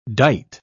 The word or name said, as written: dight